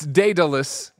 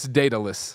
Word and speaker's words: Daedalus: Now Evan says, it's Daedalus. It rhymes with Icarus, and it is Icarus's father. So Icarus, Daedalus.